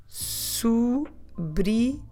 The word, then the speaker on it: sobrinhos: So os sobrinhos. We have that ñ sound that we spell with the NH, nhos, os sobrinhos.